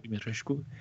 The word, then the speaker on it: Dimitrescu: Dimitrescu. Je le prononce pas aussi bien que toi, j'ai pas Dimitrescu